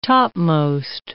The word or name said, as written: topmost